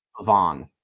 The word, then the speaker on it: Pavon: I believe it's Pavon. – Because he's French. French, I believe.